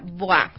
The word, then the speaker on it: Bois: A ver, el centro se llama Boa, ¿no es cierto? Pero se escribe B-O-I-S. – Exactamente, se escribe Bois. – Bois. – Se pronuncia Boa, es en francés.